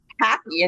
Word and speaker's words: Hapi: Happy, and it's spelled H-A-P-I, is my birth name.